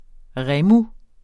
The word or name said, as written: REMOS